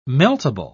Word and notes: meltable: British English

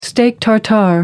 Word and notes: steak tartare: American English

